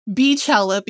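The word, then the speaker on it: Beychella: They're entitled to their wrong pronunciation. It is Beachella because her name is Beyonce. Beachella is not a thing. She's the Queen Bee, not Queen Bey. And B-E-Y, though looks like Bey, is B.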